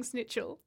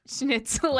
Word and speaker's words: schnitzel: No, I grew up saying snitchel. And it sounded like... – Oh, you thought you sounded kindred spirit. – Yeah, I did. Well, guess what? You didn't. I just mispronounced it. Get out of here. – It's been so hard to train myself to say schnitzel and not snitchel.